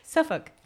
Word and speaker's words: Suffolk: Friday, August 13, 2010, at 13:13, a 13-year-old boy in Suffolk. How do you say that word? – Suffolk. It's so weird when you say it.